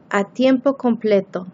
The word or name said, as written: a tiempo completo